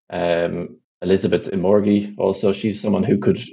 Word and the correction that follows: Omoregie: I just love how you you you, you kind of ducked out of the end of the pronunciation, like Omoregie.